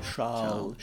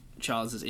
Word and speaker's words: Charles: It's actually pronounced Charles. – The Monégasque way to say it is Charles Leclerc. – Charles.